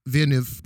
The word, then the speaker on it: Villeneuve: They probably don't pronounce the two L's as more of a V. – Yeah, he's French Canadian. – So it's more of Villeneuve.